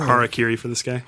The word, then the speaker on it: Harakiri for the Sky: Harakiri for the Sky.